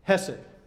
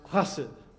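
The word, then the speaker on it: chesed (חסד): I find one clue in the Hebrew word hesed, or better pronounced, chesed, a pronunciation you probably should resist during a coronavirus scare.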